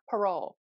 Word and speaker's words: Parol: Tina Parol.